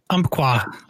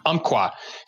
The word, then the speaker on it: Umpqua: Did I pronounce it correctly? – Umpqua. – Umpqua.